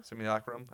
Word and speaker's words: Simulacrum: How do you say it? – Simulacrum? – Simulacrum.